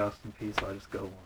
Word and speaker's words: Peay: Everybody I've heard say P, so I just say Austin P.